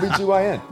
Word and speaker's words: OB-GYN: The O-G-B-Y-O-Q-B-T. – O-G-B-Y-N? – Yeah. – Yeah. – Sorry, what did you call it? – O-B-G-Y-N.